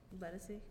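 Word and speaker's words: Ledisi: Ledesi or Ledesi? I don't know how to say it. – Ledesi? – Okay, mm-hmm. Perfect.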